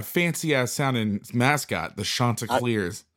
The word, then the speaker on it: Chanticleers: The NCAA gets their life together and they expand the playoffs and the Chanticleers. Did I say that right? – Chanticleers. – That sounds way fancier than how I said it.